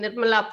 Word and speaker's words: Nirmala: and people couldn't pronounce my name Nirmala properly, so I became Nimi.